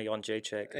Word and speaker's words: Jędrzejczyk: So Joanna Jędrzejczyk, yeah. I believe is how it's pronounced.